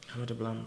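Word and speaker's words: Ama Dablam: So you mentioned, now I'm going to probably butcher this name, but Ama de Blam. – That's right. – Ama de Blam, I'm impressed myself.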